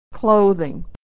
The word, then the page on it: clothing: American English